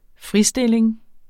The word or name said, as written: fristilling